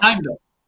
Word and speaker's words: Heimdall: I will say another one of my favorite characters in this is it Landau, the gatekeeper, the Bifrost. – Heimdall. – What is it? – Heimdall. – Heimdall. It's the way he always pronounces it. It sounds like Landau, but you know who I'm talking about.